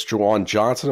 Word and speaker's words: Juwan Johnson: Juwan Johnson, am I saying his name correctly? – Mm-hmm. – Yep.